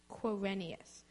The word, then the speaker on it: Quirinius: Quirinius.